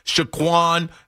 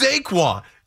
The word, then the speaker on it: Saquon: Before we go to Jim, yo, BT had me cracking up today because people kept saying Shaquan, Saquon. He's like, it's Saquon. You guys keep butchering his name.